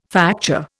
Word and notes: facture: Indian

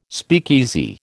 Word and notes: speakeasy: American English

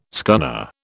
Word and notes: scunner: British English

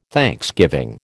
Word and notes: thanksgiving: American English

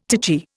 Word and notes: titchy: Indian

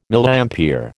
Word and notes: Milliampere: American English

